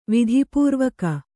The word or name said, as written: ವಿಧಿಪೂರ್ವಕ